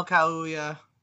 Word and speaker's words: Kaluuya: Best Supporting Actor went to Daniel Kaluuya.